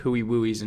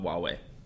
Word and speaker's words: Huawei: Huey Wooey's and Samsungs. It's pronounced hot Huawei.